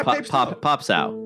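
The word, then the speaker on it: Pape Sow: Pape So? – Yeah, Pop Sow. – Pop Sow? That's how you say it? – Yes. – Pop Sow. Okay.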